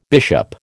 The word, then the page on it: bishop: American English